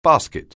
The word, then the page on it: basket: anglais